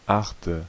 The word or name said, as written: eighth